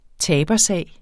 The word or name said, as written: tabersag